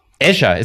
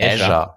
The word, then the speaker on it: Azure: Die haben doch dieses Azure oder wie spricht man das aus? – Azure, ist es wirklich, sagt man wirklich Azure. – Ja, ein Azure.